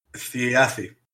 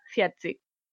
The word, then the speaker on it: Thiazi: La Z en este caso, y si seguimos el nórdico antiguo o el islandés antiguo, eh, se pronunciaría como una D o una T, como queráis considerarlo, seguida de una S, o sea, Ciazzi.